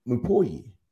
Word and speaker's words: Mupoyi: Mupoyi. Mupoyi is how I've been saying it.